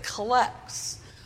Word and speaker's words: collect: We pronounce it collect in the Episcopal Church for reasons that I have never fully understood, but I imagine find their roots in our love of all things British.